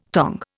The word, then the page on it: tong: Indian